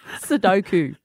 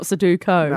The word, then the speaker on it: Sudoku: It's not Sudoku. – No, mate. Well, it depends where you're from. You can say it. If you're born in the CBD, you say it like that. Up in the hills, we say Sudoku.